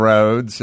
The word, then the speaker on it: Rhodes: Levee and Rhodes, if I'm saying that right.